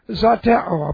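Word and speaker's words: zeteo: Present active participle of the verb zateo. I'll put it on the board since we're looking at the word. Z long E, T E long O. Zateo.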